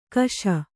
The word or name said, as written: ಕಷ